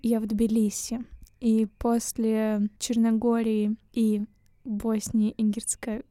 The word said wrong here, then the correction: Герцеговины: Герцеговины, Герцеговины.